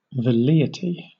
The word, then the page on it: velleity: British English